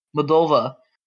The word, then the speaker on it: Moldova: Moldova.